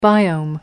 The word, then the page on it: biome: American English